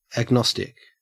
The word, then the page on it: agnostic: Australian English